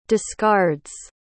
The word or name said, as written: discards